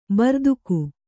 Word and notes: ಭೃತಕ: Kannada

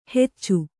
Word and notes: ಹೆಚ್ಚು: heccu

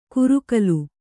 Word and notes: ಕುರುಕಲು: kurukalu

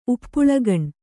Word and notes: ಉಪ್ಪುಳಗಣ್: uppuḷagaṇ

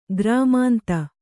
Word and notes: ಗ್ರಾಮಾಂತ: grāmānta

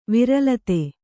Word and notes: ವಿರಲತೆ: viralate

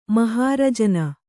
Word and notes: ಮಹಾರಜನ: mahārajana

